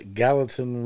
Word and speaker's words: Gallatin: Gallatin River.